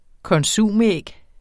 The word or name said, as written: konsumæg